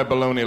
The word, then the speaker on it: bologna: Who decided on the pronunciation of the word bologna? All right, how do you want to pronounce this word? Bologna! I don't know if you saw, there's a G in the word. I don't see no G.